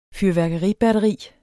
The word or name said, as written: fyrværkeribatteri